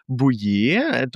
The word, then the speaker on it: Bouillet: Und heute mit dabei ist Dominik Bouillet. Dominik, sag mir, wenn ich das richtig ausspreche, hört sich Französisch an. – Hallo Victor, grüß dich, ich freue mich auch. Ja, Bouillet, genau, Bouillet, also ganz wie B-U-J-E.